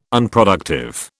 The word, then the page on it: Unproductive: British English